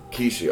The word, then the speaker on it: Kishi: Is it Kishi? – Kishi. – Okay, so it's got like the double...